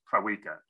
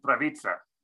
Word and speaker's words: Pravica: Yeah, the last name is pronounced Pravita, which is, well, I guess, like pizza. The W is pronounced as a V, but I mostly just go as Adrian as well. And that's easier for some folks, so... Either one, but Pravica would be the correct pronunciation.